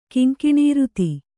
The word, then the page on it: ಕಿಂಕಿಣೀರುತಿ: Kannada